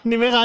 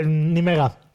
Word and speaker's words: Nimega: ¿Se escribe ¿cómo se dice? – Nimega. Ya, es se escribe Nijmegen, ¿vale? – Nijmegen, Nimega.